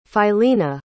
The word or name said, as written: Philena